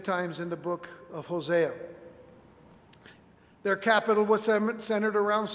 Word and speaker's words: Hosea: But it's interesting that the prophet's name, Hosea, that is how it is pronounced in Hebrew, Hosea,